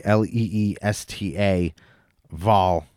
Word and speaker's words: Leesta Vall: It's Leesta, like Leesta, Vall, Vall. But I believe you just pronounce it Leesta Vall, you know, right?